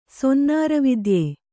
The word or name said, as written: ಸೊನ್ನಾರವಿದ್ಯೆ